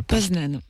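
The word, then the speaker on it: Poznań: Poznan.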